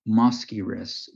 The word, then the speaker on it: Mosquirix: I believe the brand name is Mosquirix.